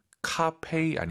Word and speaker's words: 咖啡: 우리 청취자분들 중에서 우리 선배님들 카페이 아니고 페이입니다. 카페이도 아니고 커피만 다 같이 발음하시죠. 하나, 둘, 셋. – 카페이. – 카페이. 페이 아니에요. 카페이.